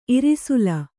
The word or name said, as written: ಇರ್ಸಾಲ್